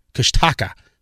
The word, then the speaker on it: Kushtaka: And then there's the, I guess you could say Kush, because the first one I read was Kush, K-U-S-H, and then a T-A-K-A, Kushtaka.